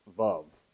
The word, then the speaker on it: vav: In Hebrew, there's a letter, which I can't even draw for you, but the English word of it is W-A-W, and you want to say waw when you see it, but it's actually pronounced vav, like in rhyming with love.